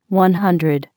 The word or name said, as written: One Hundred